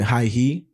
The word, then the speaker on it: Heihe: One of the cities was called, this is how I pronounce it, Haihe, H-E-I-H-E. Basically, to sum it up in like simplistic terms,